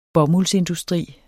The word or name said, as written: bomuldsindustri